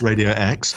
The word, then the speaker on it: Radio X: Radio Extra? You still on that thing? – Yeah. Yes. It's pronounced Radio X.